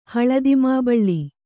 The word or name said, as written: ಹಳದಿಮಾಬಳ್ಳಿ